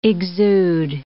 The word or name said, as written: exude